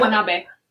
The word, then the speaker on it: Watanabe: It doesn't have an A at the end, it's an E, and in Japanese you pronounce it A, so it's B. Like yeah. – Watanabe. – Ken Watanabe. – So it's half Watanabe. And half. – Literally, it is half and half. – Because all of the vowels have the same sound, they don't change. They don't have long vowel sounds.